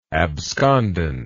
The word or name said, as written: abscondence